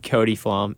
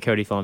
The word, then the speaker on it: Cody Flom: Cody Flom at Cody Flom.